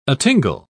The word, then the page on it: atingle: British English